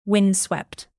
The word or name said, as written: windswept